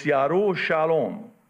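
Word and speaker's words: Yerushalom (ירושלים): The 2017 eclipse, also known as the 7 Salem eclipse, Salem, peace in Arabic, Shalom, peace in Hebrew, Jerusalem, Yaru Shalom, because in Hebrew they don't pronounce the J, In Arabic, we do. So we want the J in there. So it's Jerusalem. But in Hebrew, it's Yerushalom.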